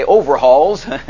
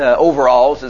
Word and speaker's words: overalls: But if some guy comes in here, as we say, overhauls, and overalls is the correct pronunciation, I know that, but he used to call it overhauls.